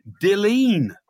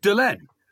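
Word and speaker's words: Dilyn: D-Y-L-A-N. And some even said to me, don't you know Carrie's Welsh? And in Wales, it's pronounced Dilleen. – No, it's not. No, the emphasis is on the first syllable.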